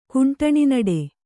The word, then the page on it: ಕುಂಟಣಿನಡೆ: Kannada